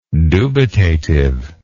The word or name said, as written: dubitative